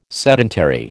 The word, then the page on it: sedentary: American English